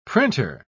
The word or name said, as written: printer